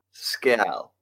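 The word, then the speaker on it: Scéal: I think it's Scale, isn't it? – Scale. – Scale, yeah. – It's spelled C-S-C-E-A-L, and it's subtitled an Irish folklore adventure.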